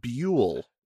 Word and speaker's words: Buell: It's pronounced Buell, like fuel, but with a B.